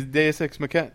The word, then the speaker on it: Deus Ex Machina: Yeah, exactly. Deus Ex Machina. Yeah. – That's the proper pronunciation.